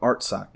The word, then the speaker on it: Artsakh: Am I saying that right? Hold on. Hold on. Art. Okay. The Republic of Artsakh.